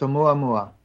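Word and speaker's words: Oumuamua (ʻOumuamua): Well, I had a brief email text exchange this morning about an object in space called Oumuamua, if I'm saying it right. Did I say it right? – You got it right. It's Oumuamua. It's a Hawaiian word.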